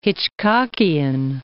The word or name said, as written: Hitchcockian